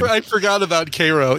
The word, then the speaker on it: Cairo: But it is pronounced Cairo, like the syrup.